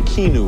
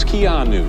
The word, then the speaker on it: Keanu: For mispronouncing your name, I was saying Keanu or something. It's Keanu.